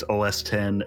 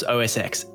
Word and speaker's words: OS X: Actually, it's pronounced 10. It's OS 10 and the iPhone XR. They don't say the X. – Pretty sure it's OS X.